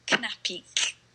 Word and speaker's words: Knapik: I would say Knapik, and that is spelled K-N-A-P-I-K, Eugeniusz Knapik.